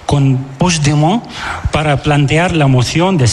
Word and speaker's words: Puigdemont: Me has preguntado antes, ¿cómo se dice este nombre? – Sí, voy a intentar. – Puigdemont. – Puigdemont. – Vale. – Puigdemont. – Eso es. – Sí, lo dices como francés, así.